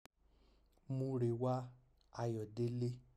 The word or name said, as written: Murewa Ayodele